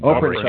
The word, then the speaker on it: Oprencak: And Oprencak.